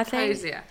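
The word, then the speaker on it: Hozier: Yeah, Hosier.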